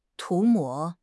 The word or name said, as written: 涂抹